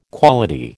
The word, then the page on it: quality: American English